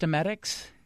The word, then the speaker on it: emetics: Is that how it's pronounced? Emetics? – Yes.